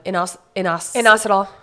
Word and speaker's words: Inositol: Inositol.